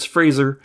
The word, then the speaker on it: Fraser: It's not Fraser, it's Fraser.